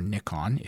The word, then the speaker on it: Nikon: both Canon and Nikon, or Nikon, if you prefer that uh, pronunciation, are currently offering very compelling mirrorless models.